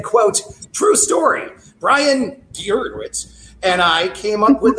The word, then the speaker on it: Gewirtz: You can only say it if you say it like that. – Gewirtz. Okay, we'll go with Gewirtz.